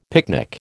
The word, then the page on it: picnic: American English